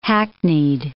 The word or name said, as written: hackneyed